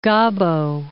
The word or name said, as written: Gabo